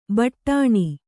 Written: ಬಡಿನ

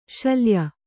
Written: ಶಲ್ಯೆ

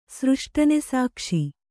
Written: ಸೃಷ್ಟನೆಸಾಕ್ಷಿ

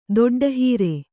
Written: ದೊಡ್ಡಹೀರೆ

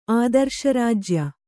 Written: ಆದರ್ಶರಾಜ್ಯ

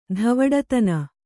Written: ಧವಡತನ

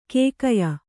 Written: ಕಗ್ಗಾಯಿ

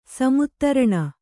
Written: ಸಮುತ್ತರಣ

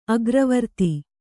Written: ಅಗ್ರವರ್ತಿ

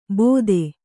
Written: ಬೋಧೆ

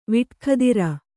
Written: ವಿಟ್ಖದಿರ

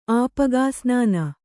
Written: ಆಪಗಾಸ್ನಾನ

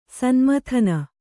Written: ಸನ್ಮಥನ

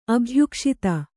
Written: ಅಭ್ಯುಕ್ಷಿತ

